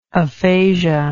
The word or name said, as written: aphasiologist